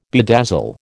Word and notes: bedazzle: American English

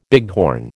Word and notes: bighorn: American English

